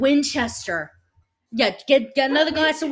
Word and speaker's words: Winchester: Okay, Winchester. – Say it, but without it sounding like wine. – Okay, Winchester.